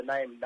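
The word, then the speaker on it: Naarm: You know, obviously the name change, and they've got a new jumper, but the name NAM Football Club. So, NAM is it? Sorry, NAM. – Yeah. – NAM. – So yeah, yep. It's spelled in a RRM.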